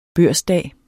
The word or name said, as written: børsdag